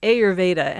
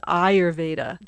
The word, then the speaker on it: Ayurveda: as in my introduction where I say Ayurveda, and the proper pronunciation is Ayurveda, just like proper pronunciation of yoga is yoga and not yogurt.